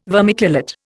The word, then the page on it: vermiculate: Indian